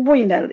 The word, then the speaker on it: Winner: Y sí, lamentablemente en esa situación todos somos Alfred Winner, dice. Y se escribe W, pero suena como winner, como ganador, como que es el único que ganó en todo este río revuelto, porque termina teniendo miles de millones.